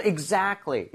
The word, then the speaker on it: exactly: So exactly 이렇게 얘기를 할 때 제가 발음하는 것만 들어보셔도 알겠지만 T 사운드가 거의 안 납니다. C하고 L이라는 자음 사이에 sandwich가 돼서 거의 안 나요.